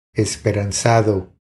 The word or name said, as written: Esperanzado